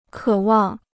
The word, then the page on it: 渴望: kě wàng